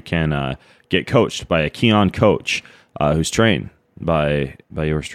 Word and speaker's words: Kion: It's all over at Keon, K-I-O-N, key, like prana, chakra, life force, energy. That's what key stands for, and that's how you pronounce it.